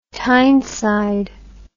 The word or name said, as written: Tyneside